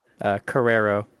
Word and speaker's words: Cararo: Cararo.